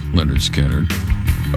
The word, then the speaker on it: Lynyrd Skynyrd: 102.5 DVE, Lynyrd Skynyrd, Simple Man, off the first album, pronounced Lynyrd Skynyrd.